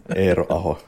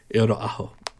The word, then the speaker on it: Eero Aho: I'm going to butcher this. Eero Aho? – Correct answer. – Oh my. Did I say it right? – Eero Aho, yeah. – Eero Aho.